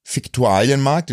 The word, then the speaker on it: Viktualienmarkt: Ja, ich gehe manchmal einfach, also manchmal laufe ich einfach Richtung Viktualienmarkt, den spricht man übrigens so aus, habe ich ja letztens erst gegoogelt.